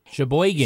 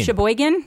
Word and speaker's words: Sheboygan: Sheboygan. – Sheboygan.